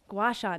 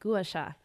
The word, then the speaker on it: gua sha: So for the gua sha, which I'm sure people out there are like, oh, that's how you say it. Gua sha, not gua sha.